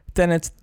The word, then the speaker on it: Tenet: Tenet, Tenet. Tenet. Tenet. Ja, je moet het precies uitspreken alsof je het ook achterstevoren kan zeggen.